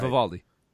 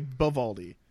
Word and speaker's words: Vivaldi: I can never say it right. – Vivaldi. – Vivaldi. I always want to say Bivaldi for some reason.